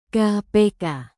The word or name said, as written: kpk